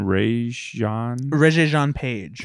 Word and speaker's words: Regé-Jean Page: What's the guy from Bridgerton, Regé-Jean Page.